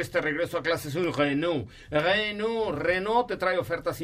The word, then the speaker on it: Renault: Renault, se escribe Renault, pero se dice Renault.